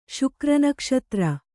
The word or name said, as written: ಶುಕ್ರನಕ್ಷತ್ರ